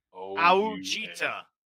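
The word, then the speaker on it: Ouachita: That is not how you spell Wichita. – That's how it's pronounced, though. Gregory Jr., cornerback, Wichita Baptist in the sixth round. – Yes, no, that's Ouchita Baptist.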